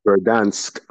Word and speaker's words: Verdansk: Verdansk? – Verdansk.